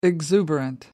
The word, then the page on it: exuberant: American English